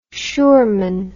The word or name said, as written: schurman